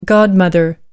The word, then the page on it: godmother: American English